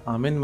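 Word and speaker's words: Amen: पर जो आमीन कहते हैं इस्लाम में, अथवा जो आमेन अथवा एमेन दोनों उच्चारण इसके मान्य हैं कहते हैं ईसाई मत में, यह तो मूलतः हिब्रू से आया।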